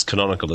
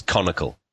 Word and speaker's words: Canonical: And people who often can't pronounce canonical as well, they pronounce it as conical.